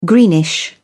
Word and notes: greenish: British English

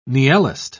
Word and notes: niellist: British English

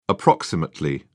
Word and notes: approx: British English

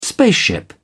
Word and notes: spaceship: British English

